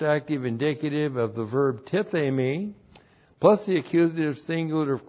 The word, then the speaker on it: tithemi: So whom, the Lord Jesus Christ, and then we have the verb tithemi. T-I-T-H, long E-M-I, tithemi.